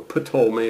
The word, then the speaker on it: Ptolemy: Ptolemy has a P on the front of it, remember? And the P is silent, so Ptolemy,